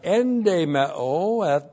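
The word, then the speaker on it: Endemao (ἐνδημέω): And this time we have endemao. That also is a con. Here's, first we had ekdemao, out of the house. Now we have endemao, in the house. E-N-D, long E-M-E, long O. Endemao.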